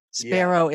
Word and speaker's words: Sperou: At one point, your your pastor Mike Sperou yeah.